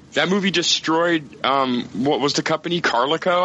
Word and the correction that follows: Carolco: I think? – Really? Oh, yeah. Carolco. – Yeah, right? – Yeah, that's how you say it.